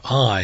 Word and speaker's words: Ai: It's called the City of Ai.